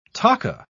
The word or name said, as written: taka